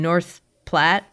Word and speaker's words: North Platte: You grew up in rural Nebraska in North Platte.